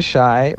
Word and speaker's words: Shai: So yeah, my name is Shai, rhymes with Thai.